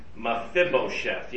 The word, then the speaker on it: Mephibosheth: Yes, Mephibosheth. That's how you pronounce it, Greg. Mephibosheth, yes.